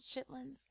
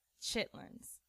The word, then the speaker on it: chitterlings: I tried chitterlings. Am I even saying that? Chitterlings? Chitlins? – I think it's spelled chitterlings. Though. – Chitterlings. – I think it is. – Chitlins. I'm from small town North Carolina. Chitlins.